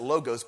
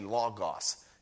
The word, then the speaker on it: logos: Some pronounce it logos, but it's actually logos. The O is ah.